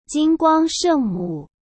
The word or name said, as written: Jinguang Shengmu